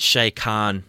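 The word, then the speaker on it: Shad Khan: Their owner, I think it's Shay Khan. I think I'm pronouncing that correctly. – Yeah, that's right.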